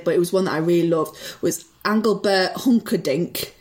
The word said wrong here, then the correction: Engelbert Humperdinck: if that's how you pronounce his name. – Engelbert Humperdinck, but yes, we, we, we knew who you were talking about, yeah.